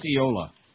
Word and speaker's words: Eola: No, it's Eola.